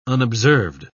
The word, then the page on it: unobserved: British English